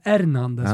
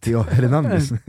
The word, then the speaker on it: Hernandez: Jag brukar säga Hernandez, ja, för att man uttalar ju inte H-et. – Nej. Du uttalade H-et så lite att det blev ett F.